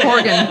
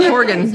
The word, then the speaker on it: Horgan: Horgan. – Horgan? – Horgan, yes. Like Morgan with an H.